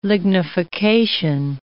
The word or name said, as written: lignification